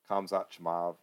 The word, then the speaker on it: Kamzat Chimaev: There's one fight on the prelims I'm intrigued with, which is Li Jinglang versus, how do we say his name? Kamzat Chimaev. – Yep. – Kamzat Chimaev.